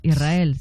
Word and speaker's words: Israel: Y la S con la R es difícil de pronunciar. La gente dice Israel. Es sin verdad. Que suene la S, Israel, sí, es difícil pronunciar.